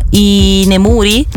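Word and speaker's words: i nemuri (居眠り): di dormire in pubblico, di fare dei pisolini, di abbioccarsi in ogni dove, soprattutto in Giappone, si chiama i nemuri.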